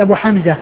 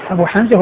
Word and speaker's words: أبو حمزة: وأبو حمزة وأبو جمرة بينهم شيء من الاشتباه, يعني من ناحية يعني من قبيل المؤتلف والمختلف, يعني تتفق الألفاظ يعني في الرسم وتختلف في النطق, لأن يعني الرسم واحد, أبو حمزة وأبو جمرة قسمها واحد, لو ما في نقط انسبس هذا بهذا لو ما في نقط, والفرق إنما هو بالنقط.